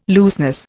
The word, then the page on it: looseness: Indian